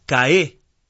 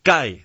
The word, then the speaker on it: cae: Bueno, Fernando dice, decía cae con tónica en la E, y no, la sílaba tónica es la A, cae, no cae.